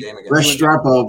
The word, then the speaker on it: Restrepo: So there, and like I said, I mentioned Restipro because you were talking about the Hurricane, so I had to mention Restipro for you, even though he Restrepo, but yeah, I got you.